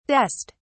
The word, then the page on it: dest: British English